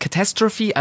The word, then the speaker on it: Catastrophe: So spricht man es aus. Catastrophe, also Katastrophe. Es schreibt sich genauso wie im Deutschen, nur mit einem C vorne, aber man spricht es komplett anders aus.